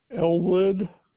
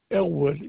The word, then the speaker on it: Elwood: Elwood Reynolds. – Okay, and can you spell that for me? – Elwood, ELWOOD, Reynolds, REYNOLDS.